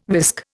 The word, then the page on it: whisk: Indian